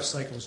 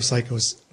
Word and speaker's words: Cichosz: I'm Jeff Cycles, J-E-F-F. – I'm sorry, Jeff. I apologize. – Jeff Cycles, J-E-F-F, last name C-I-C-H-O-S-Z.